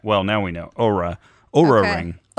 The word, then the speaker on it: Oura: It's Oura. In the logo, they use that little straight line over the O right. – to let us know that it's that long vowel sound.